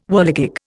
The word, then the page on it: whirligig: Indian